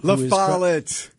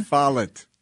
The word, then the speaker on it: LaFollette: There's a former Wisconsin State Secretary, Doug LaFossil. – LaFollette. – Did I say it wrong again? – LaFollette. – Are you sure? – I am positive.